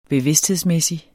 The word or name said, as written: bevidsthedsmæssig